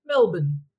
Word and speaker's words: Melbourne: So even though it's spelt Melbourne, we say Melbourne. So we sort of swallow about three letters there in our name.